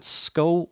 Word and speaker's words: Skov: I'll be up at Sko Winery, the recently rebooted Sko Winery, that's spelled S-K-O-V, the V silent, Sko Winery in Scotts Valley.